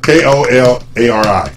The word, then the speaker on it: Kolari: K O L A K O L A R I, Kolari, Finland.